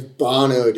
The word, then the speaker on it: Bono: Yeah, it's Bono, dude.